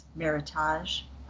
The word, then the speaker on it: Meritage: Meritage. I like the French pronunciation, as I said last week, so I'm going to keep calling it that.